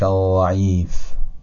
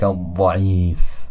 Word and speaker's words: الضعیف: و حالا کلمه ضعیف، اول اشتباهش رو تلفظ میکنم بعدا صحیح شد. دقت بفرمایید، یا لطیف ارحم عبدک الضعیف. حالا میگم یا لطیف ارحم عبدک الضعیف با فشردگی بیشتر.